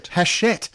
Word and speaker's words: Hachette: It's a Hachette release. So, Peter? – Hachette. – Oh, got my pronunciation. – Come on, let's do a little bit of a Hachette.